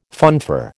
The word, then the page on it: funfair: American English